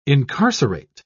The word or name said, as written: incarcerate